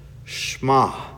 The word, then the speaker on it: Shema: But if you go back to the original language, the actual Hebrew pronunciation is Shema. It's pronounced as one syllable. Shema.